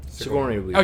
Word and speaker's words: Sigourney Weaver: Sigourney Weaver.